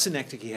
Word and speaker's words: synecdoche: Okay, so because synecdoche has its own name, does everyone know how to spell it, by the way? S-Y-N-E-C-H-O-D-O-C-H-E. Synecdoche. So the do-key part is doche, but pronounced do-key.